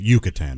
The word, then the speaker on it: Yucatan: No, you don't have to say it like that. Yucatan is fine.